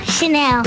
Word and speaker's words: Chanel: Chanel. – Oh. She knows how to pronounce it. It's not Channel.